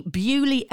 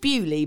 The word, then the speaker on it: Beaulieu: She says, I mean, if you said it how it's spelled, it's Beaulieu. And I love this. Lizzie, Big Biz Creative. I love Beaulieu. That's spelled B-E-W-L-E-E.